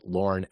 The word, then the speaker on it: Lorne: And my name is spelled Lorne, L-O-R-N-E.